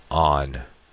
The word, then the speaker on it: On: So Egypt, and it's pronounced, On.